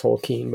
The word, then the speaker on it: Tolkien: Um, and it's pronounced Tolkien, by the way.